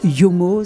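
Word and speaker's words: Jomo: Oui, parce qu'en fait, c'est l'abréviation de ses deux premières lettres de prénom. Il s'appelle Jean-Marc et il parle espéranto et le J en espéranto se prononce Yeu. Donc Yomo, c'est donc son nom d'artiste.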